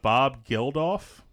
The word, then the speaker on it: Geldof: Um, cast uh, Bob Geldof, is that how you say it? – Mm-hmm.